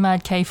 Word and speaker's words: MADK: When she pronounced it, it's pronounced Mad K. I didn't know that. I used to say MADK, so I've been pronouncing it wrong, because I was so confused how you pronounce this name. MADK, but I call it MADK because I thought it was an abbreviation of abbreviation thing, but she called it Mad K.